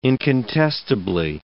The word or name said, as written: incontestably